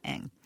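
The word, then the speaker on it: Ng: It's spelled N-G, but it's pronounced Eng.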